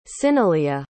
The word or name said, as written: cyneleah